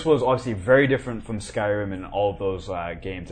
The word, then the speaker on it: Skyrim: Scrim.